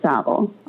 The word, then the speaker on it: Savile: I think it's Savile. – I think it is Savile.